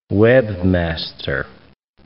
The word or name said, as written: webmaster